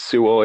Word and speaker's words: Sewell: Joe Sewell, or Joe, I believe it's Sewell is how that's pronounced, that you're talking about.